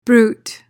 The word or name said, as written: bruit